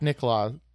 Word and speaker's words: Nicklaus: Nicklaus, I believe. – Nicholas? – It's N-I-C-K-L-A-U-S. Is that just Nicholas? – I'm pretty sure it's pronounced Nicklaus.